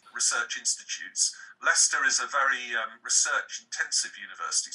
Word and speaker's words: Leicester: Now, you are the deputy director of the Digital Culture Institute at the University of Leicester. Is that pronounced correctly? – It's actually pronounced Leicester. And American people always struggle with this word. – Leicester. Okay. – It's pronounced Leicester.